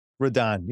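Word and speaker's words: Rodón: Roden is left-handed. – Rodon, you got to use the pronunciation.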